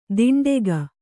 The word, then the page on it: ದಿಂಡೆಗ: diṇḍega